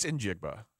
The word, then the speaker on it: Njigba: I think it's a soft I. – Yeah. – I think I said it incorrectly the first time I said it. It's Njigba. – Soft I. – Is that also the right? Eh? Yeah. Not I, but it's like Njigba. – Yeah, Njigba, and it's a hard G. It's a spoken G.